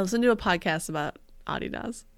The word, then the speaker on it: Adidas: Did you just call Adidas Adidas? – That's how it's pronounced. It's short for Adi Dassler, who was the person that founded it.